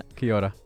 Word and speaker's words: Kia ora: It's kia ora. It's a separate word. Two separate words.